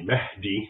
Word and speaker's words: Mahdi: So the Mahdi, an English speaker would pronounce it, it's really Mahdi, which basically means guided.